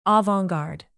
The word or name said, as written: avant-garde